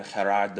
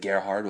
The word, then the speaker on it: Gerhard: This week we spoke with Arizona offensive lineman Gerard De Beer. That's the correct pronunciation. Everyone always pronounces it Gerhard, which is wrong.